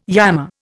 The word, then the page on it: yammer: Indian